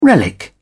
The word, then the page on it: relic: British English